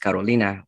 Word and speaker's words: Carolina: My name was Carolina, like South Carolina, like South Carolina, you know, like North Carolina. But yeah, it was Carolina. It was actually pronounced Carolina, which is sounds gorgeous, beautiful in Spanish.